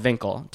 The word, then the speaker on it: Winkel: In Amsterdam, it's called Winkel, W-I-N-K-E-L, pronounced like a V.